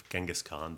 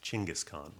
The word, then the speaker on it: Chinggis Khan: we've all heard of Genghis Khan, though the real pronunciation is Chinggis Khan.